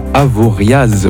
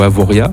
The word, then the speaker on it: Avoriaz: Au niveau mercure, il faut compter cet après-midi 17 à Avoriaz ou Avoria. – Avoria. – Avoria, merci. – On ne prononce pas le Z à la fin.